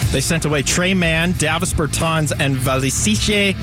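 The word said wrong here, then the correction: Micić: I butchered that to hell. Sorry. Micic and some draft compensation.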